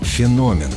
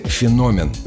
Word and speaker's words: феномен: Запомнить правильное ударение поможет простая рифма. «Космос дивен и огромен» — назовем его «феномен». Так что, если вдруг засомневаетесь, как произнести это слово, выбирайте «феномен».